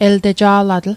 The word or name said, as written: elteja'latl/el'teja'latl